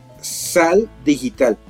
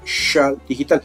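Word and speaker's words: Xal Digital: Sí, no se produce con Che. Se produce con S. Se escribe con Z. No, mentiras. Con X. Sal Digital. Pero se dice Shal Digital. Entonces es X-A-L Digital.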